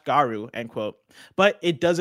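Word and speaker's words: Garou: On stage at EVO 2022, SNK announced that a new entry in the long-dormant Fatal Fury / Garu series, I don't know how to pronounce that one. – Garu, yeah. – Garu? Okay, perfect.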